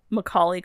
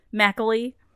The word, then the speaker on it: Macaulay: What was it? Macaulay Culkin you did the other a few weeks ago? Mackley.